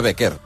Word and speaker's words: Becker: Bueno, clar, es pronuncia Becker, en alemany. – Però que vol dir forner. – Ah. – En espanyol, panadero. – Becker.